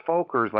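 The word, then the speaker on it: Völkers: So although it's pronounced Fokker's, like almost like an F, it's E, V as in Victor.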